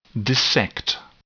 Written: dissect

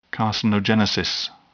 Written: carcinogenesis

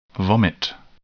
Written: vomit

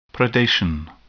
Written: predation

